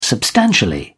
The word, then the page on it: substantially: British English